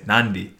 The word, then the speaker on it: Nadi: It's an invisible N. There's just an N there. You yeah. have to say Nandi.